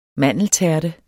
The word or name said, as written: mandeltærte